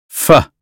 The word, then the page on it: fish: American English